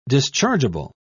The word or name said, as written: dischargeable